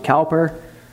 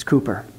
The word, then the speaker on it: Cowper: Some people pronounce it Cowper. It's Cooper.